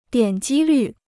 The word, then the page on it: 点击率: diǎn jī lǜ